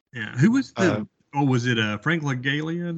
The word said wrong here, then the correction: Langella: that was the. – Frank Langella. – Langella? – Yeah.